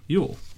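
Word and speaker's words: Jól: One particularly durable solstice festival was Jol, also known as Yule, and pronounced Yule. A feast, it's spelled J-U-L-E, a feast celebrated throughout Northern Europe, and particularly in Scandinavia, in honor of Jolnir, another name for Odin.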